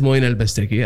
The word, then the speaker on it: Moein Bastaki: So my name is Moein Bastaki, and I'm sure that it's not a really easy name for people to remember.